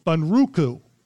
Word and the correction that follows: Bunraku: I'm not sure if I'm saying that right. – Bunraku. – Bunraku.